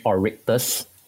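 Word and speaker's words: Oryctes: Now, you've also developed some in-house, let me see if I pronounce that correctly, the Oricytes and the Miss Drone. – Yep, it's actually named Orictus. It's a scientific name of one of the pests.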